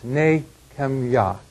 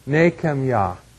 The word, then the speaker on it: Nehemiah: Nehemiah really should be pronounced Nehemiah. That's really, we probably don't do a great job of pronouncing it. It is more than likely a three-syllable word, Nehemiah, with the emphasis, of course, on Yahweh at the last syllable.